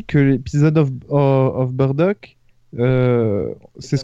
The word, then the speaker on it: Bardock: Euh, Bon voilà, donc c'est Bardock, euh, c'est officialisé. Badak, on peut le dire, euh, c'est pas entièrement faux, c'est la prononciation française de la prononciation japonaise.